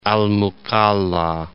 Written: Al Mukalla

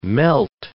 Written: melt

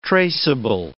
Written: traceable

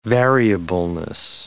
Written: variableness

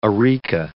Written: areca

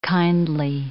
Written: kindly

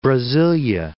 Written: Brasilia

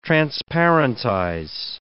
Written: transparentize

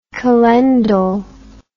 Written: calendal

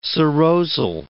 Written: serosal

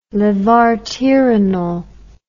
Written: levarterenol

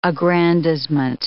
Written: aggrandizement

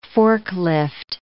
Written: forklift

